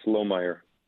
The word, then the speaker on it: Lohmeier: Well, Matt, say it again, Matt Lohmeier, is that, did I say it right? – Yes, it's Lohmeier.